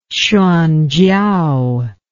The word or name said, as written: Hsuanchiao